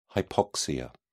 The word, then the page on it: hypoxia: British English